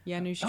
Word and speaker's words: Janusz: Well, also the, I can't say his name, Yemis Kaminsky cinematography. – Janusz Kaminsky. That's right, yeah. – Janusz, there you go.